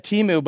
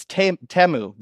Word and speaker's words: Temu: I thought it was said Timu, but if you listen to the commercial, it's Temu. That's one of the things I learned. Apparently that's how you say it. It's not Timu.